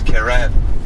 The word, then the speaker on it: Kerem: My name is Kerem. It's spelled K-E-R-E-M. And our driver's name is Cevdet, which is spelled C-E-V-D-E-T.